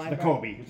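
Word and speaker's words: Nakobe: Davis, the big defensive tackle, and excuse me if I'm not pronouncing this correctly, but N'Kobe Dean? – No, it's N'Kobe. N'Kobe Dean.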